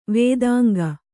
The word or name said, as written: ವೇದಾಂಗ